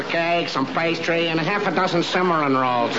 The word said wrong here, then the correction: cinnamon: Look, it isn't Cimarron, it's cinnamon.